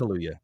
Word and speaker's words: Kaluuya: It's two U's, and I'm not sure if you're supposed to pronounce them separately. – I think it's Kaluuya, yeah.